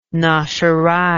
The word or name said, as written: nosherei